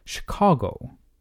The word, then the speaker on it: Chicago: Pardon me, I should pronounce that the way my neighbor Russell Ball would have me say it. Chicago, with a long A in the middle.